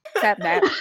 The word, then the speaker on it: Sapnap: Gosh, I can't say his name. Sapnap. Snapnap. – Snapchat. – Snapchat. – Snapnap. – Sapnap. Sapnap. Sapnap.